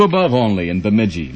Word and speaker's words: Bemidji: Lowell Thomas pronounces it Bemidji. – Bemidji. Sounds Bemidji. Like an eye test.